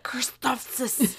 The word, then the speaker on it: Christophsis: like Christophsis or whatever. But yeah, Christophsis.